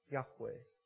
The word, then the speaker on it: Yahweh: Otherwise pronounced often as Yahweh. Right? But this is the way that it was in the original. No vowels, just YHWH, Yahweh.